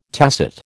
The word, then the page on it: tacet: American English